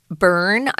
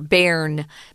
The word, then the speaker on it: Bern: I've heard people say burn, but most people, especially those who uh, read or travel at all, know that it's pronounced more with like a more air, bairn bairn.